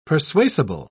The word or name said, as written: persuasible